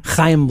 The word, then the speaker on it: Chaim: Is that how you say it? Chaim? – Chaim Bloom.